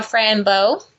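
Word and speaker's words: Fran Bow: It's called Frambo. – How do you spell that? – Fran, F-R-A-N, Bo, B-O-W.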